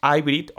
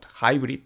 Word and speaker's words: Hybrid: Y aquí em, Samsung ha rizado el rizo y con la Flex Hybrid, o Hybrid, si queremos hablar en inglés eh, con la pronunciación perfecta, no solo tenemos un dispositivo que se enrolla, sino que también se dobla.